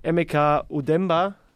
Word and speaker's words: Emeka Udemba: So, jetzt herzlich willkommen hier gerade eingetroffen im Studio Emeka Udemba. Richtig ausgesprochen, sehr gut.